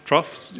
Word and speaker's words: troughs: Verse 5, your basket, and your kneading, this is a thing, troll. Is that how you pronounce that word, troll? What it is, troughs, yeah, okay.